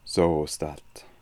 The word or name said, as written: Zoostat